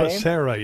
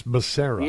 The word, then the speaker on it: Becerra: And the reason that Becerra, is that the right way to pronounce his name? – Becerra, yes, Becerra.